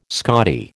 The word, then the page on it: Scottie: American English